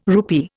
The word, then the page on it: rupee: Indian